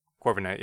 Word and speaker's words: Corviknight: Corviknight, yeah.